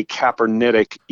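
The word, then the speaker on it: Capernaitic: Sometimes in theological works, you'll see the term, and I don't know if I'm pronouncing this right, Pastor Boyce Clark, Kaepernetic eating. – Exactly. – Is that right? – Exactly. That's exactly how it's pronounced.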